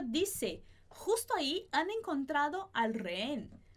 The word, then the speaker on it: justo: Justo, esse J, é aquele sonzinho aqui na garganta. Então, eu vou colocar ele com esse som do H.